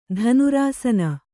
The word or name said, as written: ಧನುರಾಸನ